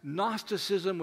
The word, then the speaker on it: Gnosticism: That's Gnosticism with a silent G at the beginning.